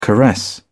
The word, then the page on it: caress: British English